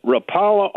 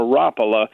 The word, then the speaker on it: Rapala: Now, whether you pronounce it Rapala or Rapala, I happen to use that.